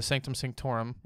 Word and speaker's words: Sanctum Sanctorum: But there's a couple scenes to where he's talking to Doctor Strange, and they're in the Sanctum Sanctorum.